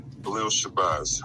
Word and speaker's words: Shabazz: Minister Shabazz? – Yes, sir. Khalil Shabazz. Yes, sir. – Khalil Shabazz.